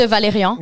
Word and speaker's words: Valérian: Même euh, le fameux vidéoclip des Valériens, je le prononce très mal, je m'excuse. – De Valérian. De Valérian.